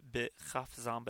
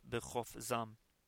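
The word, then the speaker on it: b'chofzam (בְּחָפְזָם): Looks, the word looks like b'chafzam, but it is pronounced b'chofzam.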